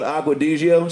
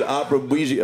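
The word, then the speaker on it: Acqua di Gio: Oh my fucking God, didn't even say it right. – Nah, he fixes phone screens. – I think you said Opera Bougie.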